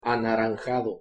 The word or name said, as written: anaranjado